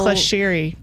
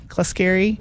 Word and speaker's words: Clesceri: I don't know how you pronounce it. Oh, wow. Clescheri. Clescheri? – Yeah. C-L-E-S-C-E-R-I.